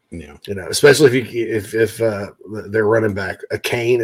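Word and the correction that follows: Achane: is it pronounced a Kane? A Kane? – A Chain? – A Chain. – A Chain, yeah.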